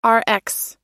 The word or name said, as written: rx